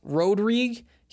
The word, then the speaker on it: Rodrigue: Oh, again, it's pronounced Rodrigue. He also goes by Coach Rod. So Rod, if it's just the shortened version, Rodrigue, if it's the long version. Now you know.